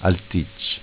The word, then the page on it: Alticc: dialetto